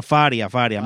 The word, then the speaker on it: Faria: Dice, Mirna Faría trabajó por... Bueno, Faria. Mirna Faria. Perdónenme, exacto. Mirna, sí, pues no hay acento en la I.